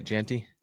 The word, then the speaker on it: Jeanty: And the idea is Ashton Jeanty, is that how you say it? Jeanty? – Jeanty, yeah. Jeanty.